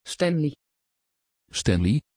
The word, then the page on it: Stanley: Dutch